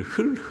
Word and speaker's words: 흙: 진토, 진토는 티끌, 흙, 흙이라는 말입니다. 흙, 흙이라고 발음하지만 뒤에 이자 붙으면 흙이라고, 흙임을 기억하심, 흙임을 기억하심이로다.